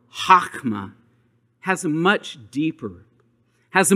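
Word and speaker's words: chokmah (חכמה): Wisdom in Hebrew is chokmah. Let's try saying that, chokmah. H-O-K-M-A, chokmah. Now say it with a little bit of saliva. Chokmah, chokmah. Yeah, now you got it. That's how you say it.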